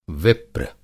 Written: विप्र

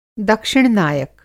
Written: दक्षिण-नायक

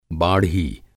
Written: बाढ़ी

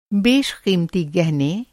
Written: बेशक़ीमती

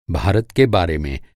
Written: के बारे में

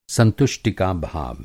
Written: संतुष्टि

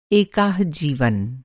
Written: एकाह

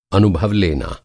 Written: अनुभव

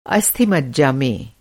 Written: मज्जा

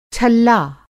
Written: छल्ला

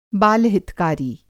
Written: बाल-हितकारी

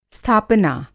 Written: स्थापना

